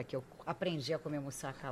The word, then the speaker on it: moussaka: Eu falava errado, eu falava moussaka, é moussaka.